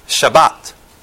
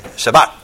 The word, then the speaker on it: Shabbat: And this is actually pronounced Shabbat. Okay? Shabbat.